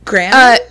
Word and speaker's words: Grandin: It's Grandin.